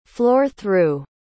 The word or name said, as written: floor-through